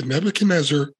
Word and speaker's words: Nebuchadnezzar: It's the Nebuchadnezzar.